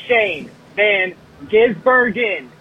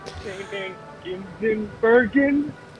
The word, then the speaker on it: Shane van Gisbergen: The man's name is Shane Van Gisbergen. Sound it the f- out. It's not that hard to pronounce. Shane Van Gisbergen. That's what you say every time you reference this poor f***ing man. It's not that hard. Sound it out. Gisbergen!